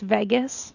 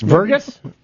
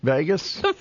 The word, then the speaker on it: vagus: It's pronounced Vegas. – Open Vagus. – Vergus? – Vagus. – Vergus. – Fly to Vegas.